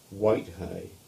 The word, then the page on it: Whitehay: English